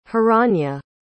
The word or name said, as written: hiranya